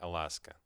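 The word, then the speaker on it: Alaska: Также Ласку я назвал Лаской, потому что штат Аляска по-английски произносится как Аласка.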